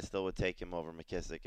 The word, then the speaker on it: McKissick: So I'm trying to debate between Sonny Michel and J.D., McKissick, that's the way you pronounce his name? – Yeah, McKissick.